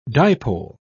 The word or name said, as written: dipole